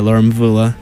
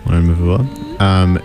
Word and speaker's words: Laura Mvula: Recently, we had Green Gardens, the Gemma Gemma remix by Laura Mvula. Am I pronouncing that right? – Yeah, Laura Mvula. – Laura Mvula.